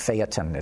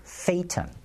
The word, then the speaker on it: Phaeton: I got to mention, Peter's rant this week on his website was all about the Volkswagen Phaeton, and now I'm being criticized for pronouncing it Phaeton. It's Phaeton.